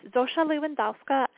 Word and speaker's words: Zosia Lewandowska: I mean, Socia, is it Socia Lewandowska and Hania Silberman? – Yes, it's Zosia Lewandowska and Hania Silberman.